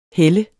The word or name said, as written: hæld